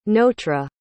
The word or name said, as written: notre